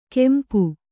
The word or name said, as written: ಕೆಂಪು